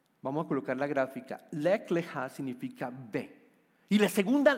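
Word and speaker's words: lech leja (לך לך): Está compuesta por dos palabras en hebreo, que son leja, que, básicamente se pronuncia como lech leja.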